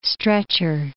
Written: stretcher

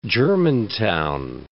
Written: Germantown

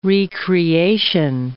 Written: re-creation

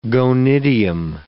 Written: gonidium

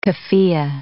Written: kaffiyeh

